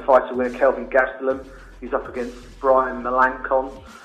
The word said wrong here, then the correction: Melancon: Melanson. I should get his name right, shouldn't I?